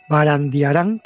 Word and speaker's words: Barandiarán: y darte cuenta del trabajo fundamental que han hecho los investigadores en el pasado, en este caso Barandarian, Barandiarán, que siempre lo digo mal.